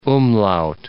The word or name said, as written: umlaut